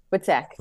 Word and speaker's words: Witczak: It's been so long, I forget how to say your last name. Witczak? – Witczak. – Witczak, beautiful.